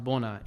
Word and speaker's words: Bona: But it's very unique, and even in my culture, like in the Oromo language, it's pronounced Bona.